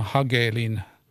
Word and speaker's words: Hagelin: Mutta tuota niin, eh, se, semmoiset, kannattaa tutustua semmoisiin henkilöihin kuin John, John kirjoittaa tai lausutaan Hegelin, mutta hän tuo on ruotsalaisperäinen nimi, eli John Hagelin.